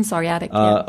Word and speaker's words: psoriatic: Okay, polyarthritis, psoriatric arthritis. – Yeah, psoriatic.